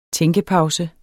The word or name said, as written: tænkepause